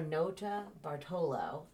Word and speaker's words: Notabartolo: Leonardo Notabartolo.